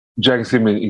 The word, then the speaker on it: Jiang Zemin: And I can see how Jiang Zemin, or was as I had, is that how you pronounce it? Jiang Zemin? – Jiang Zemin, exactly.